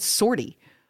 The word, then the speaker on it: sortie: Shinji is, how do you say this word? Sortie? – Yeah. – Yeah. – Like you're like a mission, S-O-R-T-I-E? – Yeah, I've only read it. – Yeah, sortie.